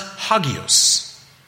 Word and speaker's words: hagios: Now, I'm no Greek scholar, but when I click the link and the little speaker to hear what the word is, it's pronounced hagios.